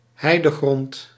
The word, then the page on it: heidegrond: hei·de·grond